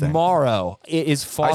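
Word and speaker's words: Moreau: I saw an interview with his mom, who basically said, "We pronounce it Morrow, but most people pronounce it Morrow." – Well, then it's Morrow. Morrow. I mean, just because everyone else is wrong, his mom said it's Morrow.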